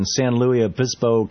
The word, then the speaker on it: San Luis Obispo: Robert in San Luis Obispo, California. Am I pronouncing that right, Robert? – You certainly are.